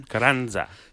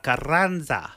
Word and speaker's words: Carranza: You just roll your R's, boo. – Just got to roll them R's. – I can. – Carranza. – Sara Carranza.